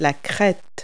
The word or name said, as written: crest